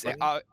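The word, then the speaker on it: Thurmond: I can't quite remember the spelling or the pronunciation, but is it Senator Thurman? – Yeah, it is. I didn't realise. I googled him afterwards, and there's a D on the end, so it's Thurman.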